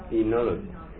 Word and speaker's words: enology: onology. – That means the comes from to brew. It is fermentation. Fermentation. – Yes. – How do you pronounce that? – Enology.